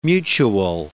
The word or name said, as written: mutual